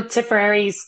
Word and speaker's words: Tipperary: I'll put a link on our homepage, because if you're a horse enthusiast, you're really gonna love it, about the County Tipperary, the breeder. And tell me if I'm saying it wrong, but I'm just in love with this podcast. – No, Tipperary's correct.